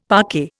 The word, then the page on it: parquet: Indian